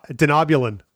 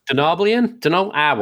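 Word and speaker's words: Denobulan: Denobian. Did I say that wrong? – Yeah, totally. – Wow. – Denobulin. – Denobulian? Whatever. Dr. Phlox.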